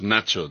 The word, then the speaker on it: Nacho: You must use Nacho. That is my nickname, and it's very more convenient. Nacho, like nachos.